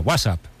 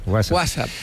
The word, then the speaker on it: WhatsApp: No, peor aún, con acento en la primera. WhatsApp, ¿no? Un WhatsApp. – WhatsApp.